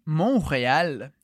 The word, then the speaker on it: Montréal: Como que a gente diz Montreal em francês? Montréal. Montréal. Então, em francês, a gente também escreve o T, mas a gente não pronuncia.